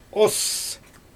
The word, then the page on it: oss: Xyzyl